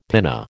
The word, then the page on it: pinna: American English